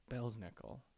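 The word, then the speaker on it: Belsnickel: Belznickel. – Belznickel? – Yes. It's spelled B E L S nickel. – Belznickel.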